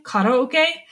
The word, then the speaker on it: karaoke: Okay, so we're gonna move on to the karaoke scene, or if you want to pronounce it the Japanese way, karaoke.